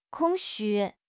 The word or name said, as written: 空虚